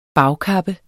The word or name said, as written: bagkappe